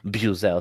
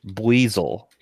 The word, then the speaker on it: Buizel: And by the way, it's not Buzel, it's Buizel.